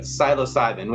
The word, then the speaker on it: psilocybin: Yeah, so we're going to talk about psilocybin, which is derived, that's the medical ingredient, if you will, or the hallucinogen ingredient in magic mushrooms.